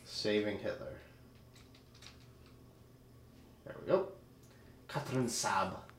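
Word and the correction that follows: Saß: No, Sass. – Is that how you pronounce that letter? – Yep, it's two S's together. It's why in the credits her name was S-A-S-S.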